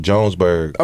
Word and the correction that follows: Johannesburg: Okay. – Yeah, and he's Johannesburg. – Johannesburg. I'm sorry, I've never been there.